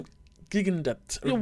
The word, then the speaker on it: Cam Gigandet: Cam Gigandet. – Whatever. Gigandet, yeah, I'm that sure.